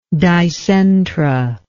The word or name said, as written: dicentra